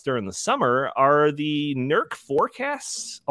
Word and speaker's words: NERC: So the author of this piece talks about a recently released assessment forecast from the North American Electric Reliability Corporation, or NERC. Is that how you pronounce it, Isaac? – That's correct. – NERC.